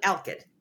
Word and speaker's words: alkyd: How do you pronounce that again? – It's walnut alkyd.